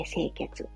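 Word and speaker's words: 井穴: ので、まあね、時間がある方だけ引き続き聞いていただきたいなと思うんですけれども、まずね、すごくいつでも押しやすい、巡り力アップは、清血というツボがね、手の指の爪の際のところ、末端の際のところにあります。漢字は、井戸の井に穴って書いて清血って読みます。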